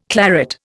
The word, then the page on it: claret: Indian